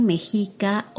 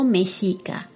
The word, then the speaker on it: mexica: Azteca es como se le conoce en los libros de historia y geografía, pero el nombre original de este imperio era el imperio mexica o mexica. Se puede pronunciar de ambas formas porque la palabra proviene de el náhuatl,